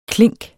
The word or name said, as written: klink